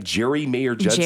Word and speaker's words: Geri Mayer-Judson: Anyways, Jeremy or Judson back again. Ooh. I say that right? Jerry Mayer Judson. – Jerry Mayer Judson. I know, triple barrel name. It's easy to mess up.